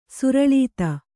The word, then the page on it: ಸುರಳೀತ: suraḷīta